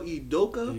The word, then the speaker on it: Udoka: Emile Udoka.